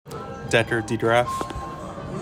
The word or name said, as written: DECKER DEGRAAF